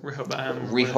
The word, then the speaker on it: Rehoboam: let's see, Rehoboam. I don't know how to pronounce it. – Rehoboam. – Rehoboam or something.